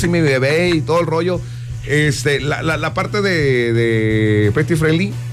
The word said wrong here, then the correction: Pet Friendly: ¿sí se dice así? – Sí, Pet Friendly. – Pet Friendly. Sí.